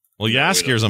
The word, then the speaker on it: Jaskier: It's hard to say because it's like Jaskier, I believe, is how you pronounce it.